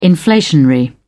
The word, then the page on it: inflationary: British English